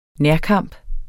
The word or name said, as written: nærkamp